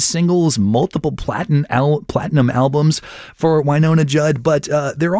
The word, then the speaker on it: Wynonna: No, not Winona. Wynonna. Sorry, Minnesotans. That's how that's pronounced.